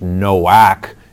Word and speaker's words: Noach: Now, Noah, the masculine form of the name, such as Noah, who built the ark and was on the flood, which is actually pronounced Noach in the Hebrew language, if you translate it, means rest.